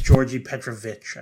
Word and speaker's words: Đorđe: It was almost like a J sound at the beginning. – Yeah, the like Serbian D with the bar is a DJ. It's a DJ. So yeah, it's Đorđe Petrović, I believe.